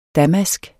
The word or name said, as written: damask